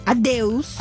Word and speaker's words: adeus: Adeus.